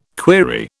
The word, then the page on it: query: British English